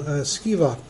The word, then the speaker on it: Sceva: And seven sons of, how do you pronounce his name? Sceva. Seven sons of Sceva, a Jewish chief priest, were doing this.